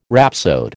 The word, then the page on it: rhapsode: American English